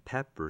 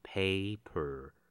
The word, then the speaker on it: pepper: Salt and pepper seasoning 就是胡椒盐。 – 这边我们来练习一下 pepper 胡椒的发音。 Pepper 千万不要念成 paper 纸，很多人经常把这两个音发错，那别人自然不知道你要表达什么。